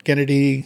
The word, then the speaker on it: Gennady: Gennady? – Yes.